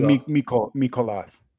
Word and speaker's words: Mikolas: It's Wainwright and Miles Michaelis and Miles Mikolas. Okay. That's how you say it in the true term.